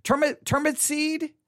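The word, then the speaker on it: Turnipseed: I'll just spell it. Turnip seed. SEED. I'm trying to break it down, but turnip seed. That's how you would pronounce his last name.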